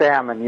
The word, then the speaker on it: Ammon: Yeah, it's Ammon. You said it right.